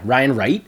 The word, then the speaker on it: Ryan Wright: So yeah, Ryan Wright. Did I get his name right? – Yeah, I believe so.